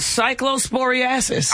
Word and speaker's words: cyclosporiasis: Yeah, but it's called cyclosporiasis.